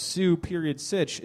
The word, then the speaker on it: Susich: He spells it phonetically on his business card, Sue.Sitch. It makes sense. Am I saying it correctly? – That is it.